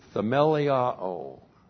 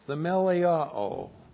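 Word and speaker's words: themeliao (θεμελιόω): The Greek word is themeliao. looks like that in the Greek, THEMELIO, long O, themeliao.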